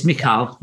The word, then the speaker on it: Michal: And is it Michal? Is that how we're going to pronounce her name? Michal. It looks like Michael. – Yeah, it's Michal in Hebrew.